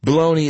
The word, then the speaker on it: bologna: Bologna isn't even spelled close to the way it's pronounced. I'm not sure how we decided on the commonly used pronunciation.